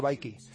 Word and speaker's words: BKIE: Hay una aplicación, también hecha en España, que se llama BKIE, y como ellos dicen en la descripción, que se pronuncia Bikey.